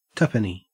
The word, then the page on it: tuppenny: Australian English